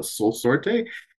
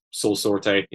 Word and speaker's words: Sorte: I've been saying Soul Sort, but it's Soul Sorte.